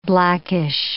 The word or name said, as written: blackish